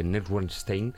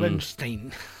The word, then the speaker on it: Neuschwanstein: Hombre, no cabe duda que estos dos lugares, básicamente, que has comentado, tanto el Alcázar como el castillo de Neuschwanstein, son... – Neuschwanstein. – Bueno, ¿cómo se pronuncie? – Me está recordando esto al jovencito Frankenstein. – Neuschwanstein. Ahora, Neuschwanstein.